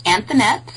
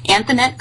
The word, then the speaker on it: Anthonette: It's Anthonette, so the feminine of Anthony, Anthonette Klinkerman.